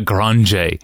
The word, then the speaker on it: Grande: Well, it's spelled Grande, G-R-A-N-D-E. – Yeah. – And and they said, oh, that's fine. You can say it that way. But everybody who said it in Brazil said Grande.